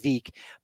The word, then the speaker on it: Veeck: It was somewhat of a publicity stunt by Bill Veeck, who has a book called Veeck as in wreck, even though it's pronounced like Veek, but he did a lot of crazy promotions, Demolition Disco Night.